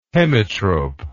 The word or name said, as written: hemitrope